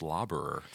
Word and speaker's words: Laberer: So my last name's actually pronounced Laberer. Fun fact.